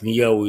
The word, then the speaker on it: Нахуи: Не доходят они и до деревни Нахуи, которая читается как Ньяу и расположена в Перу.